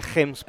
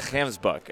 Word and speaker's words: gemsbok: We call it a gemsbok. – A gemsbok, and that's the Dutch influence, right? – Yeah, that's the Dutch pronunciation of the G.